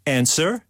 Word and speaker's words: answer: Silent P, right? Answer? – W. – W and Neil.